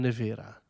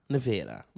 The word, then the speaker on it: Nevera: Hoe moet je het eens uitspreken? Is het Voltron Nevera? Nevera? Volgens mij Nevera. Nevera. – Ja. Dat is, Voltron Nevera.